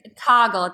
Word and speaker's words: Toggl: It's Toggle, T-O-G-G-L.